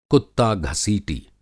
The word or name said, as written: कुत्ता घसीटी